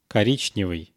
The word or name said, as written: korichnevyy